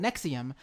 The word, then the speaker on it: NXIVM: I would call it Nexium. I have it spelled out Nexium, NEXIUM. So I would remember to call it that.